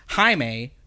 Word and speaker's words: Jaime: So I was like, because recently I saw an Injustice clip of Batman talking about Blue Beetle, and he was like, Jaime, right? – Because he's Latino.